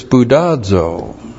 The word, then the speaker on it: spoudazo: And the key word there is spoudazo, which looks like this, S-P-O-U-D-A-Z, long O, spoudazo.